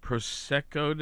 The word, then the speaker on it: Prosecco: National Prosecco Day, is that how you say it? – Mm-hmm.